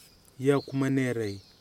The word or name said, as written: Yokmaneri